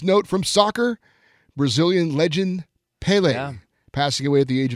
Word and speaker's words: Pelé: I believe it does have the accent on the E there. – Pelé.